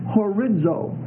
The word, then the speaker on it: horizo: The word determinate in Greek is horizo. H-O-R-I-Z-O. H-O-R-I-Z-O. That last O is a long O.